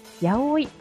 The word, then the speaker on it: 801: 場所は御園橋 801 広場と書いてやおいと読みます。